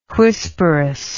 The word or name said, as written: whisperous